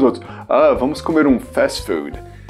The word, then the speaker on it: fast food: A pronúncia do inglês fica fast food. Tem esse i no final que a gente fala em português, que é o food. Né? Esse i você corta fora se você quiser falar em inglês.